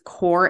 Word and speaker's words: Cour: So we are now, that's exactly why your, so like it's Cour Experience, but it's spelled C-O-U-R.